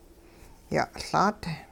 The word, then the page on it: ya'Laat: hupa